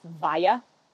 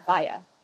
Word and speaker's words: Walla: I always try and tell people that my name is pronounced Valla. W-A-L-L-A, naturally. It's Valla. But no one bites.